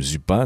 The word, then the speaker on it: Zupan: Kim Zupan, ou Zupan peut-être, ça s'écrit Z-U-P-A-N, mais comme le monsieur est originaire du Montana aux US, je dirais Kim Zupan.